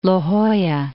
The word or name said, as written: La Jolla